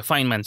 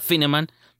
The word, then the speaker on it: Fineman: Y descubrí que realmente se pronuncia Feynman. Si fuera Fineman tendría dos N's. Es Feynman, como fine de bien, Feynman.